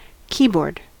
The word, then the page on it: keyboard: American English